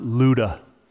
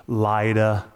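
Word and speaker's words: Lydda: Now, this is pronounced Luda. It looks weird. It looks like Lida. But the guy in the Strong's Concordance that pronounced it to me before the service said Luda. So I'm going to say Luda.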